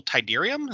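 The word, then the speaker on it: Tydirium: Imperial Shuttle Tidarium? Is that how you say it? – Yes.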